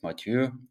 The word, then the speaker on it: Mathieu: Monsieur Mathieu, ja, der, die französische Aussprache halt.